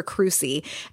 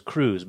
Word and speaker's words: Crusie: I thought it was Cruz, but it's Crusie. C-R-U-S-I-E. – S-I-E.